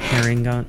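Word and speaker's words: Harengon: Like hair, it's spelled H-A-R-E. – Okay. – N-G-O-N. Okay. Hair, like herring gun, like, you know, like.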